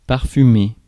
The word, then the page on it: parfumer: France; [paʁ.fy.me]